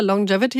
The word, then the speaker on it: Longevity: Longevity habe ich richtig ausgesprochen? – Korrekt.